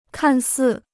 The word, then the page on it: 看似: kàn sì